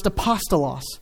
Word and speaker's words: apostolos: The Greek word is pronounced apostolos, so you can hear the Greek word in the English word.